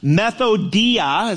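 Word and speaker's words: methodeia: Methodia is how you say it in a slow sense. It's where we get the word method from.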